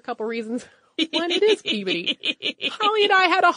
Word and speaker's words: Peabody: I could be mistaken since it is the museum name, but the town of Peabody is pronounced Peabody.